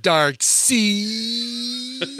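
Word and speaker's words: Darkseid: The villain's name pronounced Dark Seed.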